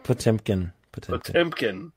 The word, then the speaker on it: Potemkin: But yeah, you can find all our shit there, and we're gonna come back with Battleship Potemkin. Did I say that right? – Potemkin.